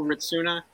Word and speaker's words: Hrycuna: It's Paul Ritsuna. My last name is spelled H-R-Y-C-U-N-A.